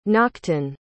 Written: nachton

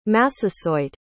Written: Massasoit

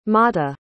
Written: matta

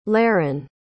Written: leryn

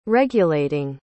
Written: regulating

